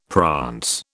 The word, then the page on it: prance: British English